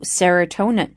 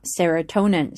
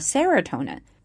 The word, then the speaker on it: serotonin: 这里我们要注意一下 release 这个单词后面的 ea 呢发的是一个长音 e release 我们在读的时候一定要注意这里的音节呢不要把它读成了短音 release release release release 色氨酸释放了血清素 serotonin serotonin 这个词呢注意一下它的重音是落在 t t 这个地方 serotonin serotonin 不要把它念成了 serotonin The serotonin 到底是什么东西呢？